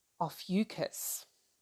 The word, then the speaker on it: Ophiuchus: Ophiuchus.